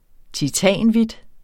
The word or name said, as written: titanhvidt